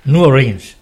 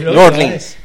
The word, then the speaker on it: New Orleans: No, antes cuando has dicho New Orleans es New Orleans. – Es New Orleans. – Como pronuncian ahí.